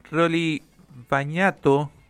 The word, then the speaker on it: Rolly Bañato: A partir de mañana ya tenemos nuevo programa y vamos a hablar con uno de los conductores, Rolly Bañato. No sé si lo pronuncio bien. Rolly, ¿cómo va? Bienvenido al aire. – Perfecto, perfecto.